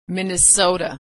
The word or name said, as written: Minnesota